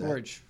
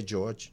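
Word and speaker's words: Jörg: It's J-O-R-G. Somebody tell me how to pronounce it. – George. – George? – Mm-hmm. – Okay, George. You know who you are. You're the only J-O-R-G on the show.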